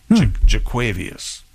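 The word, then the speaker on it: Jo'Quavious: Yeah, J-O-apostrophe-Q-U-A-V-I-O-U-S Marks, M-A-R-K-S, Jaquavius.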